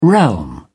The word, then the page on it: realm: British English